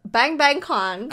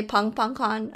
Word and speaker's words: BangBangCon: It's kind of weird because you know they say pang tan son yun dan. So I'll just say pang pang con.